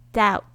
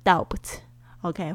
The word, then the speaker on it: doubt: doubt 注意一下，b 不要发发音哦，你不要念成 doubt，OK 会很傻哦。